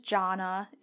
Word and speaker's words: Jonna: Like I said, my name is Jonna. It's spelled J-O-N-N-A.